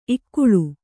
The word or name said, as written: ಇಕ್ಕಳು